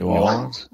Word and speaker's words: New Orleans: I need to say it like you say it. New Orleans. How do you say it? – New Orleans. – What's the right way to say it? – New Orleans. – I always want to put the R in there.